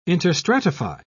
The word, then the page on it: interstratify: British English